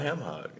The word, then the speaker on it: hock: It sounds like a ham hog. Is it a ham hog? No. Have I been saying that wrong all my life? – No, it's hawk.